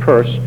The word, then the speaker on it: Peirce: His intellectual associates included Charles Sanders Peirce, looks like it should be pronounced Pierce, but it's pronounced Peirce.